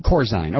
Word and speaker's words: Corzine: It's Corzine. Okay, I pronounce it Corzine. It's Corzine. Thanks.